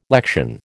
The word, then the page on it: lection: American English